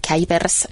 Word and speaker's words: Kuipers: Joo, sehän lausutaan jotenkin hienosti Kuipers ja se on hollantia.